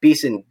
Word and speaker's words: Behson: I have my last name is spelled kind of oddly. So if you put Scott, S-C-O-T-T, Behson, B-E-H-S-O-N into like Google or anything, I will come up because I'm the only one in the world.